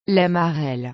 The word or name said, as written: la marelle